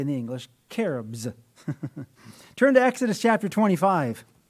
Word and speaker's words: cherubs: The first category of angels that the Bible identifies there in Genesis 3 are cherubs, sometimes mispronounced as cherubs. But in the Hebrew, the CH is the hard K sound, cherubs.